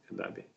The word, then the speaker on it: Adobe: А, она, может быть, это индийское название, вот она с «и» на конце она читается, звучит «эдаби».